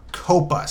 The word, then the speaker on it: COPPA: There is a law called the Children's Online Privacy Protection Act, or COPPA, C-O-P-P-A.